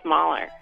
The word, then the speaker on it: Mahler: All right, I'll go with Mahler. – Yay! Oh. – My next clue was it rhymes with Schmaller.